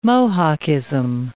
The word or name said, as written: Mohockism